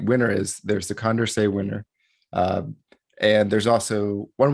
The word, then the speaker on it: Condorcet: So it's named after a French guy, Marquis de Condorcet, who was very interested in voting theory in his day. It's spelled C-O-N-D-E-R-C-E-T. The T is silent. Like those French folks, they don't like to pronounce the T.